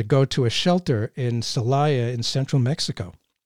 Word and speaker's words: Celaya: It's a remarkable endeavor to travel to a shelter in the central Mexican town of, I hope I pronounce this right, Celaya, to collect, yeah. – Oh, good, that's nice, to collect stories from immigrants.